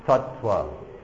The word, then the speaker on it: tattva: When there are double letters, they both get pronounced. So tattva. It's not tattva. It's not pronounced as if there were only one T. Tattva. Okay? Double letters, both of them appear. There are no dropped consonants. Each one gets distinctly pronounced.